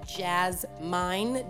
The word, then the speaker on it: Jazzmine: Also, my name is spelled Jazzmine, J A Z Z M I N E.